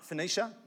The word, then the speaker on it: Phoenicia: Phoenicia, thank you, and Samaria, describing in detail the conversations of the Gentiles, and brought great joy to all the brothers.